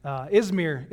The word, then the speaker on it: Izmir: I was told I said it wrong last time. Izmir. So don't screw that up later. Izmir is what it's called now.